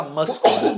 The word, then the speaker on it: muscle: E tem muita, é, muita é, letra muda, né? Muda ou surda, não sei é. como é que fala isso, né? Que, que não se pronuncia, que tipo, passa batido, né? Então, tipo, você lê uma palavra, tipo, é, é, a gente fala. É, muscle, né? Você fala muscle. né? Saúde. É muscle. Muscle.